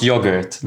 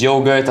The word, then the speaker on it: yogurt: And she said it's pronounced yogurt. Like we speak English. Mm-hmm. Not American English. It's like American English is yogurt and English is yogurt.